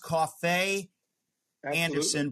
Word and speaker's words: Coffey Anderson: You seriously, Coffey, it's pronounced Coffey Anderson, but that's easy compared to your wife's name.